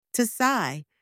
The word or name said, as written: to sigh